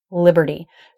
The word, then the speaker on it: liberty: In this case, the E vowel, unstressed E, liberty. So when the T comes after an R before a vowel, it is a flap T. And that's a really different pronunciation than a normal true T.